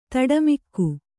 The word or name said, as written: ತಡಮಿಕ್ಕು